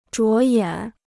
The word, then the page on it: 着眼: zhuó yǎn